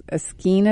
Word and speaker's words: skein: And that didn't By the way, it's pronounced skein. – Skein? Okay. – Rhymes with cane.